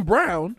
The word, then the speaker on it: Braun: It was that guy was, is it Christian Brown? Because it looks like it's spelled Braun. – But all I know, they call him Brown.